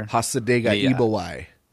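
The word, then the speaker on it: Hasa Diga Eebowai: I listened to that, I'm going to butcher it. The Asa Debo Igawai or whatever. – Hasa Dega Igawai.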